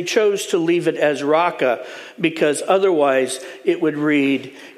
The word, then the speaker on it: Raca: Now, I know it looks like paka, but trust me, that's a row, okay? So, raka.